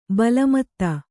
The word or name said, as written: ಬೆಲ್ಮತ್ತಿ